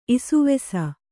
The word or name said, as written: ಇಷ್ವಾಸ